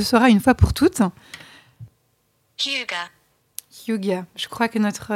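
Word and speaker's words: hygge: au moins maintenant on sait qu'il faut prononcer hyuga comme on vient de le voir.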